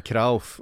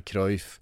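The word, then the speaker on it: Cruijff: Det ska också vara Johan Krauf och inte Cruyff.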